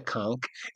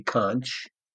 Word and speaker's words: conch: We call it the conch. It's spelled conch, and the rest of the world spell say conch, but in this part of the world, this local part of the world, I'm saying local, real local, not region, it's called conch.